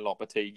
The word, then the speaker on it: Lopetegui: Is that how you say it? Julian Lopetegui. Yeah.